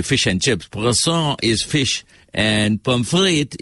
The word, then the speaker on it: poisson: Right, so poisson is spelled P-O-I-S-S-O-N. Right? So you better be very careful how you pronounce that word because he might just bring something that will kill you.